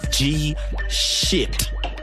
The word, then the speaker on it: Gshytt: Because his name is spelled G-S-H-Y-T-T. But it's actually pronounced shit. G-Shit.